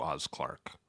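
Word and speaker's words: Oz Clarke: So I should call you Oz Clark. That's pronunciation. – Oz Clark is the way to pronounce it.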